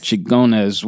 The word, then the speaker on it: Xingones: Xingones? – Xingones.